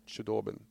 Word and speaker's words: Chudobin: Uttalar man så? Chudobin. – Ja, men det är nog säkert rätt.